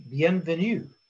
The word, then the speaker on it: bienvenue: So Julie, bienvenue. Did I say it right? – Yeah, très bien.